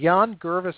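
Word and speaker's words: Yangervis: Jan Gervais is how you say his first name.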